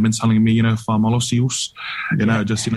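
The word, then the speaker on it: fa'amalosi: So it's pronounced like fa malosi. – Fa malosi.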